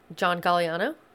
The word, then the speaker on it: John Galliano: John Galliano.